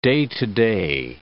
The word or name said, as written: day-to-day